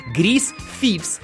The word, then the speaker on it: Grease Thieves: Grease Thieves.